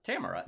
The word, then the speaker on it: Tamara: Tamara is how that's pronounced in that show.